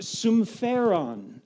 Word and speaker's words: sumpheron: This word is a Greek compound word that is pronounced sumpheron.